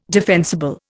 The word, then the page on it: defensible: Indian